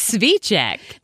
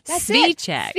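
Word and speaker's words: Cviček: It starts with a TSV. It's Cvicek. Cvicek.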